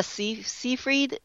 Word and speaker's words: Seyfried: Amanda Seyfried, is that how you pronounce her last name? – Yeah.